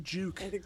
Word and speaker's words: Juke: But the Nissan Juke. Which rhymes with puke.